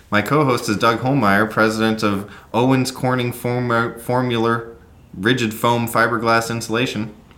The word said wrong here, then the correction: Foamular: How do you say it? – Owens Corning Foamular Rigid Foam Fiberglass Insulation.